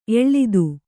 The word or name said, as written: ಎಳ್ತು